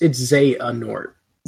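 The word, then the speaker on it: Xehanort: So well, Xenohort, if we're going to call him that, even though that's how will you actually pronounce it? Is that it was Xenohort? – Zahanort. – It's Zeanort. – Zeanort. – Yeah. – Oh, I put the emphasis on the wrong syllable.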